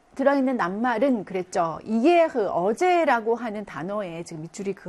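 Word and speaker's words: hier: 이 이에흐는 지금 이 다음에 이렇게 모음이 지금 연달아서 나오고 있죠. 그러면 모음이 모음과 만나면서 모음의 체력이 조금 약해지죠. 그래서 우리가 반모음이다라고 해서 발음이 이가 아니고 이렇게 발음이 약간 좀 세력이 약화되는 거죠. 이게 이런 발음이 나는데 그거를 고르면 되는 거예요.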